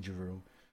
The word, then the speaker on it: Giroux: My last name is Giroux. A lot of people don't know how to say it in English. I say Giroux. I'm not shy to say my name is Giraux.